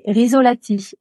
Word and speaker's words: Rizzolatti: Alors, c'est G-I-A-C-O-M-O. Alors, Giacomo. Et Rizzolatti, R-I-Z-O-L-A-T-I.